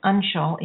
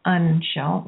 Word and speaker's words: Anshul: So, it's Anshul, even though it's spelled A-N-S, it's pronounced Anshul, like U-N-S.